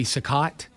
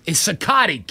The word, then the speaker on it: Cicotte: It's because I pronounced his name as Eddie Cicotte. Then it was only when I went to a baseball card show that an old man told me, it's Cicotte, kid.